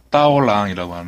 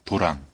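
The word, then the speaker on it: 따오랑이라고 (刀郎): 제목은 일단, 아, 가수의 이름은요, 예, 따오랑이라고 하는 그런 가수입니다. 도랑. 우리 이제 한, 한자어로 발음하면 도랑이라는 가수인데, 아, 중국 그 서북부 신강 위구르 자치구 지역이죠.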